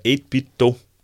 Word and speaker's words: 8BitDo: der hedder 8-bit Do, tror jeg. Man tror det, som man udtaler det, ikke? Altså 8-bit og så Do, ligesom Nintendo.